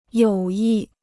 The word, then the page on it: 友谊: yǒu yì